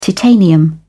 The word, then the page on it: titanium: British English